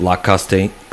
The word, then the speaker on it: Costeña: So we have La Costeña, C-O-S-T-E-N-A.